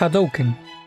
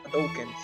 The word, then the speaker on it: Hadouken: ¿Y cómo se pronuncia bien? Es Hadouken. – Hadouken, sí. Hadouken se pronuncia.